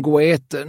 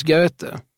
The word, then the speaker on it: Goethe: Johan Wolfgang Goethe. Nej, det uttalas Goethe.